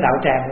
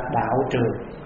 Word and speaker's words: đạo tràng: Thì nên hai cái chữ đó chúng ta phát âm cách nào cũng được hết, đạo tràng cũng được, giống như cái áo quý vị mặc, áo trường cũng được, áo tràng cũng được.